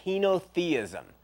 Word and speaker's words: henotheism: This stance in theology is called henotheism,